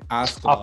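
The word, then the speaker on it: Askeladd: Askela. How do you pronounce it again? – Askela.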